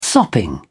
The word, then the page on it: sopping: British English